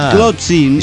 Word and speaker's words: Tlotzin: Bueno, Lotzin. Lotzin. Y vayamos familiarizándonos con esto que se escribe T-L-O-T-Z-I-N.